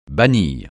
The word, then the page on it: bannir: francais